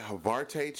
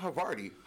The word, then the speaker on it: Havarti: No, this chick said, yeah, can I get some of that Havarte cheese? Not Havarti, Havarte. Oh, she done respelled it, repronounced it in the whole fucking nine. Not Havarti cheese, Havarte.